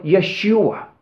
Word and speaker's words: Yeshua: You pronounce it in Hebrew like Yeshua.